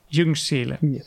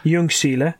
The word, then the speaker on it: Ljungskile: I don't expect you to be able to pronounce that, but it's Ljungskile. – Ljungskile. – Oh, very good.